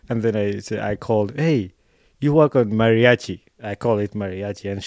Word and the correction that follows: Meraki: So, Meraki. It is pronounced as it is, Meraki.